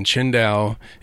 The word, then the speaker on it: Qingdao: it says Tsingtao, yeah, but it's pronounced Qingdao.